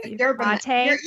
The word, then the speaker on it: yerba mate: They're based in Texas and they do like, how do you pronounce that? Herbamate? Is that how you say it? – Yerba mate? – Yerba mate.